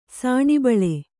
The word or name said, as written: ಸಾಣಿಬಳೆ